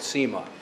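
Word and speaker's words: nsima: it's called Sema. If you're looking to spell that, it starts with an N. The N is mostly silent.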